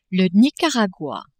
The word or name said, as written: Nicaragua